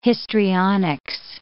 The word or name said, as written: histrionics